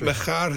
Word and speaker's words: Machar: How are you spelling that? – M A C H A R. – Yeah. – Yeah. – Against. – What is it? – Macha. There's a famous Macha bar in Aberdeen and Macha's Cathedral.